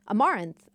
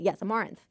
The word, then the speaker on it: Amaranthe: Amaranthe. Amaranth. – Amaranth. – I think that's how you say it. Yes, Amaranth. I was adding way too many syllables in there.